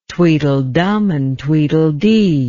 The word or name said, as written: Tweedledum and Tweedledee